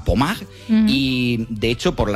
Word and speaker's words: Pommard: O sea, estamos hablando que son imágenes que si las buscáis, sí, Pommard, se escribe Pommard, como si fuera en castellano, pero Pommard, pues allí en Francia, hablamos de unas imágenes que podían pasar.